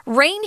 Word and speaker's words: reign: Reign here is pronounced the same way as xiaoyu, or just that water that comes from the sky.